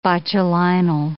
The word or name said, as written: botulinal